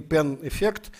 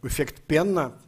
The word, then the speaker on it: пен-эффект: Повышение, общее повышение уровня цен, это, это известный пен-эффект, названный, многие, кстати, неправильно его называют, эффект Пенна, пишется пен-эффект, но пен – это сокращение,